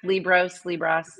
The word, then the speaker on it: Liebross: Yep, Liebross. You know, whatever you want to say. Liebross. Right. I always like to say it rhymes with gross.